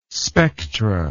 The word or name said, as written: spectra